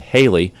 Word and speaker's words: Haley: Until 1952, WPWA radio station program director Bob Johnson suggested Haley, rhymes with Bailey, take advantage of the common mispronunciation of Halley's, rhymes with Sally's Comet.